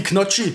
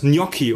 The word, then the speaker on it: Gnocchi: Genauso wie Knocchi, ne? Ich fress heute Knocchi. Oh, ey. Rassisch Gnocchi. Wie, wie spricht man es richtig aus? Gnocchi, oder? Gnocchi, ja.